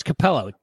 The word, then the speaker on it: Capello: It's got Tim Capaletti. How do you say his name? The sex guy. Capello. Oh, it's Capello.